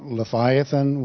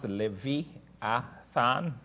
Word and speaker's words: Leviathan: So you do notice that I pronounce Leviathan, and you guys pronounce it in English, Leviathan. That's fine. In Hebrew, you say Leviathan. Okay? All right. Just as the last time, the last part of tan is like the word Satan. You say Satan. So that's what that last part of it is pronounced, the same as Satan.